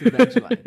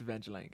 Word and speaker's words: Evangelion: It's Evangelion. It's Evangelion, guys.